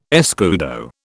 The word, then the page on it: escudo: British English